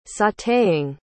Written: sauteing

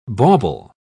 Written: bauble

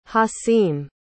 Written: hasim

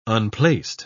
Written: unplaced

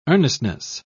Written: earnestness

